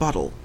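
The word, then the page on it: bottle: Canadian English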